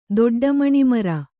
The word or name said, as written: ದೊಡ್ಡಮಣಿಮರ